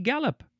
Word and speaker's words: galop: It is spelled G-A-L-O-P, so it looks like gallop or galop, but it is, well, gallop.